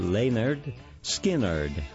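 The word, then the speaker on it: Lynyrd Skynyrd: This year marks the 50th anniversary of the group's debut album, pronounced Leonard Skynyrd.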